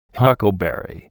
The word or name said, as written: huckleberry